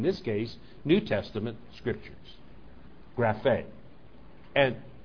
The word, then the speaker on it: graphe: So I looked up the word for scripture, and it's graphe, G-R-A-P-H-E, pronounced graphe.